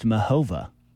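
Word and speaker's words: Muchová: Christian told me it's pronounced Muchova. – Oh, Muchova. Okay. Well, it's a silent C is wild, especially in a Czech name.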